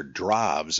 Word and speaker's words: Drews: Well, there's a great book by Arthur Drobs, I think you pronounce it, it's spelled D-R-E-W-S, called The Legend of St. Peter.